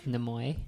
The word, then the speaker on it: Nimoy: Nimoy.